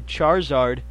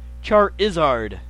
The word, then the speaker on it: Charizard: You can say Charizard. Charizard. Because it's like a lizard.